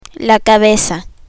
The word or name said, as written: cabeza